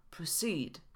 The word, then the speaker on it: proceed: W filmiku o stronach internetowych pojawiło się słowo proceed. Wymówione było proceed. Ten czasownik składa się z dwóch sylab, pre i seed, gdzie akcent pada na drugą sylabę.